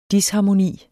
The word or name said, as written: DISHARMONIEN